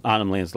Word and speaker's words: Arnhem Land: Arnhem Land's like Northern Territory, Australia. – How do you say it? – Arnhem Land? – Arnhem Land. – Arnhem? – Arnhem. – But how you spelling that? – It's A-R-M-H-E...